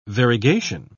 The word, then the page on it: variegation: British English